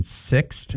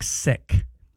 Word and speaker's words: sixth: She said a word that people mispronounce is sixth. You know, when you're saying sixth, yes, like fifth, right, or fourth or sixth. She says they say it like sick, and it drives her bananas.